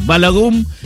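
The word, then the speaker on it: Balogun: Llamando a la FIFA para decir que estaba mal echado el jugador Bogalum, ¿cómo es? Ahí te lo digo bien. Y se empezó a pudrir todo a partir de eso, porque la FIFA dijo que sí. Balagum fue expulsado y Folarin Balagum, opa, va a jugar hoy contra Bélgica en Seattle.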